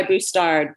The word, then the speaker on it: Bustard: To, my mother-in-law goes by Bustard, but it is like mustard, but rhymes with a. It is like mustard, but rhymes with a B.